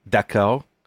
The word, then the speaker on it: d'accord: It's D'ACCORD. – D'accord. – Is that it? D'accord? – Yeah.